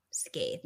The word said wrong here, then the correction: scythe: No, scythe. – Scythe. – What has it been? Sai. Like the Grim Reaper weapon.